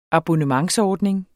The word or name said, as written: abonnementsordning